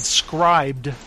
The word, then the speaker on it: Scribd: yeah, it's called Scribed, or it's spelled weird. It's like uh, S C R I B D.